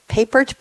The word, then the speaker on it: Papert: I'm going to pronounce it Papert, P-A-P-E-R-T, Papert.